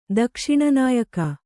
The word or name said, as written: ದಕ್ಷಿಣನಾಯಕ